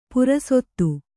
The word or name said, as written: ಪುರಸೊತ್ತು